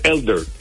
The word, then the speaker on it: Elder: Tú dices, eh, Bryce Eder. – ¿Qué? – El lanzador de los Bravos. – ¿Cómo se dice? Eh, Bryce Eder. – Bryce Elder. Elder. Sí, Elder. Eh, los mejores pitchers en ganar Saiyón.